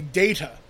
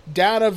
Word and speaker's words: Data: So at Big Apple Comic Con, Brent Spiner gave a panel about why he thinks Patrick Stewart has led us to say Data now. So it's weird for this. He says that the pronunciation of the word differed to Spiner's. To settle the data versus data debate, Gene Roddenberry created a new rule for the show on the spot.